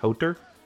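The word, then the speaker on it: Hother: I think it's H-O-T-H-E-R, I think is how you spell it, and I think it's pronounced Hodr.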